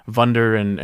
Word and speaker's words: Wunder: But I was going to also ask, why do you guys keep pronouncing it Vunder and Willi or whatever when there's clearly a W there? – They're German words. So. – Yeah. They're German Wunder.